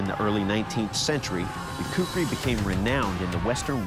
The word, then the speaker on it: kukri: I believe it's a Kukri knife. – The Kukri.